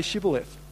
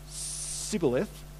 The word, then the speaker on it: Shibboleth: If he replied no, they said, all right, say Shibboleth. If he said Sibboleth, because he could not pronounce the word correctly, they seized him and killed him at the fords at the Jordan. 42,000 Ephraimites were killed at that time. This is like death by dialect, because they couldn't pronounce SH.